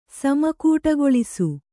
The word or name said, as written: ಸಮಕೂಟಗೊಳಿಸು